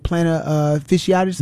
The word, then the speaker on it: plantar fasciitis: It sounds like she may be dealing with plantar fasciitis.